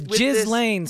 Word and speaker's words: Ghislaine: Okay, no, but I'm saying it's Ghislaine. Say it right.